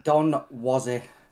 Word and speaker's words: Donwozi: It's Donwozi.